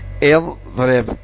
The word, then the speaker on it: اضرب: قال: فإذا قلت مثلا اضرب، اضرب، هذه همزة وصل، اضرب، فإنك تنطق بالهمزة هكذا اضرب،